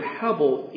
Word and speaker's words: hebel: Well, the word itself, this word vanity, in Hebrew, and that's why the title actually has that word there, H-E-B-E-L, hebel.